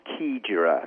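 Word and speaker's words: Xigera: It's our first Safari Lodge for Red Carnation Hotel, and it's called Kigera. And that's X-I-G-E-R-A. So pronunciation's different to the spelling,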